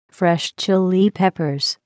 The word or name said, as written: Fresh Chile Peppers